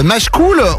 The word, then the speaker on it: Machecoul: Machecoul, on prononce ça comme ça ou pas, Marie? – Oui, oui, on prononce ça comme ça.